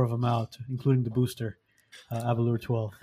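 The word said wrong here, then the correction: Aberlour: So that's not how you pronounce it, apparently. It's what do you mean? – Abelour.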